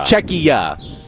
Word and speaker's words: Czechia: I don't know how to pronounce it. Is it Czechia? Czechia? I don't know. – Czechia. – A.K.A. the Czech Republic.